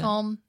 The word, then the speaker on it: Colm: That is Colm. Is that how you pronounce it? – Colm, yeah.